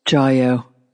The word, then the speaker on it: Jiyo: Is it Jivo, Jivo? Which is it? Jiyo. J- I knew that.